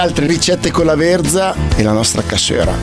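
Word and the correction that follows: cassoeula: No, non si dice cassuera, lo dici solo tu, si dice cassuela, sei l'unico che lo dice con la R.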